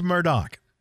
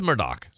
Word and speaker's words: Murdaugh: Is it Murdaugh or Murdaugh? Let's go to the Netflix special pro over here in the newsroom. – It's Murdaugh. – It is Murdaugh.